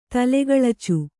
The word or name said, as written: ತಲೆಗಳಚು